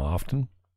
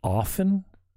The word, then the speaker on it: often: The T is silent? How? Like, I was so bummed when I said, I'm like, oh, I do say often. There's no T, the T, how come I say often then?